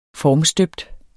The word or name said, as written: formstøbt